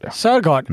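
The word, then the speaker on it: Søgaard: Jag såg igår i Expressen att en av Sveriges mest intressanta personer, den före detta frikyrkopastorn Runa Sjögård, är det så det uttalas? – Sörgård.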